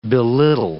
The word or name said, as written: belittle